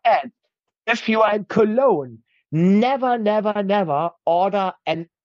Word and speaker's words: Köln: Köln. In Germany, you speak it Köln. It's pronounced in Germany Köln. Yeah, and the foreign people say Cologne.